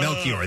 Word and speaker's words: Melchior: The last part of it is spelled C-H-I-O-R, so I thought it was choir. – She's the blonde from Peacemaker. – Yeah, Melchior. That's it.